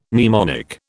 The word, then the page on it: mnemonic: British English